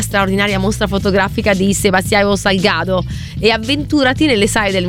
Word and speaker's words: Salgado: Tra l'altro è Sebastiano Salgado, scusate, la giusta, no, Salgado è la giusta pronuncia.